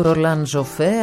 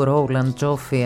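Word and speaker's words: Roland Joffé: Ο Ένιο Μωρικόνε έγραψε και αυτό ήταν ένα απόσπασμα από την μουσική της ταινίας «Η Αποστολή» του Ρολάν Ζοφέ, αν θέλετε να το προφέρουμε γαλλικά, ή του Ρόλαν Τζόφι, αν θέλετε να το προφέρουμε αγγλικά.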